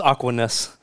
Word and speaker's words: Aquinas: But it's Aquinas.